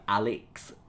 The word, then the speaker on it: Alix: She spells her name A-L-I-X. She also pronounces it like Alex, like with the emphasis on the second syllable, which Amira herself has trouble sort of working out how she should actually say it.